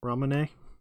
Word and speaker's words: Ramune: Ramune.